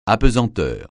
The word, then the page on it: apesanteur: francais